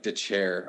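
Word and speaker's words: Dethier: Dylan, how do you say that guy's last name? – Dylan Detlier or Detlier. – I think it's like DeChair. – DeChair. – I think it's pronounced really weird, but he's in it.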